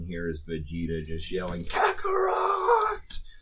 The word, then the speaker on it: Kakarot: Dragon Ball Action RPG Karate? I think that's how you pronounce that. – Kakarot.